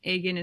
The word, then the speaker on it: Agen: Agan is spelled A-G-E-N, and yes.